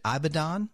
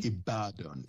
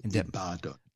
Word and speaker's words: Ibadan: Now, you're a native of Nigeria, but you did receive your undergraduate degree from the University of, pronounce that for me, is it Ibadan? Did I say it correctly? – Ibadan. Ibadan. – This is why I should have asked you before we got on the air. Wasn't even close, so thank you for the grace.